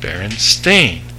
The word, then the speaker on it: Berenstain: I had a teacher come up and say it's spelled Berenstain, S-T-E-I-N, but it's pronounced Berenstain, S-T-A-I-N.